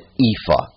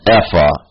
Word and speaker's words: ephah: Maybe you're accustomed to anglicizing it and pronouncing it ephah. I think that's the way most pronounce it in English. This is actually, this word is a transliteration of a unit of dry measure. An ephah is how you would pronounce it in Hebrew. And so since it's a transliteration, since you're not actually translating it into another language, you're transliterating it, we might as well pronounce it the same way, ephah.